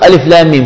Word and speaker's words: الم: أنت بدك تنطق ألف لام ميم شو بتقول؟ ألم. إذا بدك تنطق الحرف بمسمياته باسم بالمسمى الذي، لكن هو ينطق باسمه ألف لام ميم، ما بتقول ألم، كذلك ما بتقول صه بتقول صاد، ما بتقول قه بتقول قاف، فتنطق الحرف باسمه لا بمسمى.